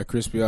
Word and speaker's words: Crispy: Yeah, Crispy Akiyama.